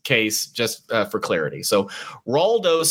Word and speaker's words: Raldo: The tough guy, did you say his name was? Ronaldo? – Raldo. – Raldo. – It's R-A-L-D-O, Raldo.